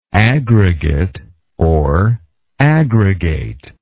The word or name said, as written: aggregateness